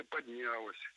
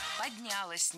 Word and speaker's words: поднялось: Как это предприятие поднялось? Как там развилось? Когда его двигали там с одного предприятия на комплекс предприятий? – Поднялось неверно. Правильно, поднялось. Ударяем окончание. Развилось тоже не лучший вариант.